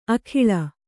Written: ಆಗುಳಿ

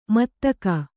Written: ಮುತ್ತಗ

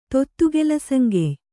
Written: ತೊತ್ತುಗೆಲಸಂಗೆಯ್

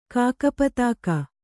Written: ಕಾಕಪತಾಕ

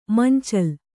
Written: ಮಂಚಲು

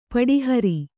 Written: ಪಟಹಾರಿ

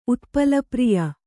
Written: ಉತ್ಪಲಪ್ರಿಯ

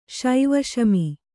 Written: ಶೈವಶಮಿ

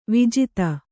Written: ವಿಜಾತಿ

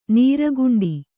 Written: ನೀರ್ಗುಂಡಿ

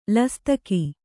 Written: ಲಸ್ತಕಿ

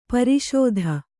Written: ಪ್ರಸ್ತ

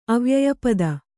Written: ಅವ್ಯಯಪದ

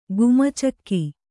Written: ಗುಮಚಕ್ಕಿ

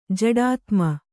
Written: ಜಡಾತ್ಮ

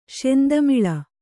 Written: ಶೆಂತಮಿಳ

